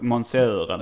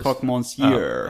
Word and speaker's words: Croque monsieur: Croque monsieur. Croque monsieur, ja. Fast du uttalade ja. väldigt, du sa monsieuren. – Ja, precis. Croque monsieur.